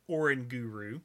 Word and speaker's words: Oranguru: Noctowl, Munchlax, Linoone, Orangaroo, Vigoroth, Greedent. Or, I'm sorry, let me say it correctly. Oranguru, I'm sorry. It, it, I look at that and I say orangaroo.